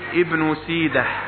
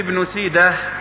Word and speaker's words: ابن سيده: وهو من الأسماء الأعجمية تقرأ كما يعني ينطقها أهلها، سيده ابن يعني اسمه هكذا ابن سيده، وليس ابن سيده، فليس هذا من باب الإضافة كما نظن، بل هو اسم أعجمي، علم أعجمي يقرأ كما هو.